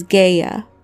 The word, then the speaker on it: Gaia: It is worth noting that an alternative way of pronouncing Gaia is Gaia, so Titia could also be known as Gaia.